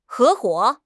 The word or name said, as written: 合伙